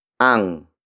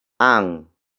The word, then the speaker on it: ang: Word for word, it's nasa, akin, ang, pitaka, mo. Nasa, akin, ang, pitaka, mo.